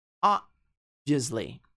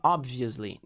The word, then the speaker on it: obviously: Obviously. Você não vai pronunciar o B, você não fala obviously. Não, obviously. Obviously not.